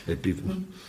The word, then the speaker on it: Epiphanes: Epiphanes. Ze hebben een regel, gewoon de klemtoon op de tweede letter grepen.